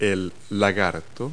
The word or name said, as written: lizard